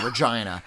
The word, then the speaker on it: Regina: Blair from Regina again. You know, the city that rhymes with fun. Well, maybe I should say Regina.